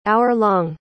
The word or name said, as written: hour-long